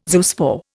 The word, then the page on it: zoospore: Indian